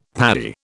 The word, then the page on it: Paddy: British English